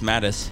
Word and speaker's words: Mateusz: According to his father, he calls Matus. Matus? – It's actually just Mattis. – Oh, Mattis? – The Z is silent.